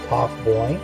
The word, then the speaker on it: Taughboyne: So it's spelled W-A-R-K. About 1792 in the Taugh Boyne, which is spelled T-A-U-G-H-B-O-Y-N-E, Tomboyne, Count of the County Donegal and Ireland.